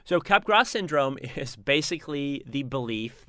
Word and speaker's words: Capgras: C-A-P-G-R-A-S, pronounced Capgras, named after a French scientist? – French doctor, yep.